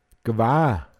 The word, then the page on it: Håoscht geh(n): Pinzgauer Mundart